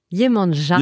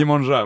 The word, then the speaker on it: Yemanja: Yemania, si je le prononce bien, j'espère. – Yemanja. – Yemanja, voilà. Mais, au Brésil, au début. au Brésil, ils, le disent plus comme tu l'as dit.